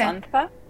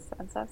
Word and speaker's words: Sanza: I mean, the Z in Spanish, we say like a TH, like th, okay. So it's okay. Sanza, but Sanza? Everyone calls Sanza, so it's okay.